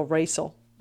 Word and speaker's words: racial: I mean, racial doesn't look like it should be said as it is spelled. – Yeah, it doesn't. It doesn't. There should be an SH in there.